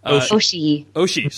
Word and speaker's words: Oshii: Or I'm sorry, 1995 film Ghost in the Shell, directed by Mamoru Ishii. – Oshii, sorry.